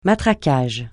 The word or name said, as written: matraquage